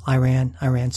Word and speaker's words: Iran: Our lead story this week is Iran, or as I often call it, Iran. My wife always tells me that I'm pronouncing it incorrectly because it's not Iran as in, you know, Iran so far away. It's Iran.